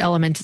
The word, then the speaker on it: LMNT: It's LMNT is, I believe, how it's spelled, but element is how they say it.